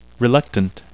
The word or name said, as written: reluctant